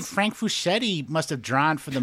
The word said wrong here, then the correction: Frazetta: You called him Frank Fuschetti. It's Frazetta. Yeah, I mean, it's probably Frigetta or something, but it's an A.